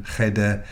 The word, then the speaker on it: Cheder: The locals pronounce that CH like cheese, cheddar, rather than Cheder, the Hebrew or Yiddish for a room, a gathering space, a school.